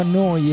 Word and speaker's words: Neu: Si scrive Neu, si pronuncia Noi e vuol dire nuova, nuova emittente urbana da Bologna.